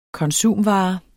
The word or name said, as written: konsumvare